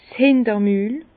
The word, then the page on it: 's Hinter-Mül: Bas-Rhin; Herrlisheim